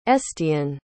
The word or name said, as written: estienne